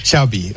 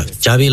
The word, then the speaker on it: Xavi: Xavi. – Okay. Yeah, Xavi like chocolate.